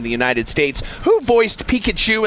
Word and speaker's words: Pikachu: Am I saying that right, Kevin? – Pikachu.